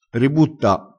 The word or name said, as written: ributta